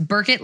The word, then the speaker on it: Burkitt: So it's Burkitt lymphoma.